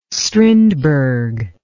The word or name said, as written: strindberg